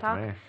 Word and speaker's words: Montauk: The Montauk Project. I'm saying that right, right? – Montauk? – Montauk? Yeah. Montauk is this series of secret U.S.